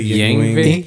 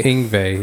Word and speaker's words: Yngwie: How do you pronounce Malmsteen's first name? – I don't know. – I say Yngwie. Yngwie, yeah. He's probably listening to our pod and...